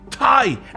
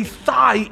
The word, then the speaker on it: Thai: It's been reported that when Ron was dating back in college, he would mispronounce the word Thai, as in Thai food, and say thigh instead.